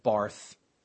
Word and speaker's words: Barth: The most famous rebel was a Swiss named Karl Barth, B-A-R-T-H. It looks like Barth. It's pronounced Barth.